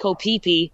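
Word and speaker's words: Koh Phi Phi: It's actually called Koh Phi-Phi, but it's spelled P-H-I, so my Irish accent just can't seem to get the pronunciation correct, and it's just embarrassing for myself.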